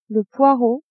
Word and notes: poireau: female

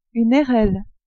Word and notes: airelle: French

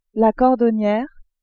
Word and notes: cordonniere: female

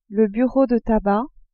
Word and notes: bureau de tabac: female

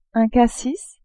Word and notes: cassis: French

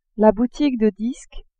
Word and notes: boutique de disques: female

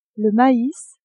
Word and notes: mais: female

